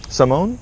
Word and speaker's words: Simone: Simone. – Yeah. Okay. – How do you spell that? S-O? – S-I. M-O-N-E.